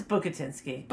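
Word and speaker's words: Bukatinsky: What if we're pronouncing his name wrong? I just saw him in The View, so I feel we're not. – Bukatinski or... – It's Bukatinski. – Bukatinski. – Bukatinski. – Let's call him Bukatinski.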